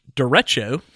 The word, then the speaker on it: Derecho: I believe there's a website called Drecho Derecho. it's like D E R and then Echo, right? That's how it's spelled.